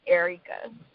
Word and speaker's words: Erica: But it's the same way that it's spelled is Erica, so it's Erica.